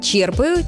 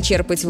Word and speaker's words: черпать: У глагола «черпать» ударение устойчивое, оно сохраняется и в других формах – «черпаю», «черпаешь», «черпают», «черпал». Само слово знакомое. Можно черпать воду из ведра, а можно черпать вдохновение или знание. Чтобы запомнить, помогает простая мысль. Если вы что-то берете из глубины, ударение тоже остается в основе.